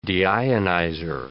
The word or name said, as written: deionizer